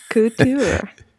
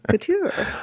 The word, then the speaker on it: couture: And couture. – See, I can't even say it right. Couture. – It's French, so it's couture.